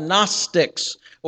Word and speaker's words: Gnostics: We've already mentioned there is a heretical cult being formed called the Gnostics, or Gnosticism, and there's a silent G at the beginning of that word.